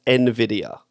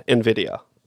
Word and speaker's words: NVIDIA: NVIDIA is NVIDIA. – Oh, like, is it really? N, and then NVIDIA. Yeah, NVIDIA.